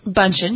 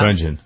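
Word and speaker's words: Bündchen: Giselle Bundchen, is that how I never pronounced her name right? – Bundchen, you did pretty well. – Bundchen, okay.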